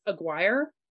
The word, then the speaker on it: Aguirre: Aguirre? Aguirre? Aguirre. – It almost looks um, like Aguilar, but it's Agu- Aguirre.